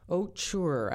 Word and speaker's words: Ocher: Let's hear from Mary Ocher. Ocher, actually. I looked this up how to pronounce it.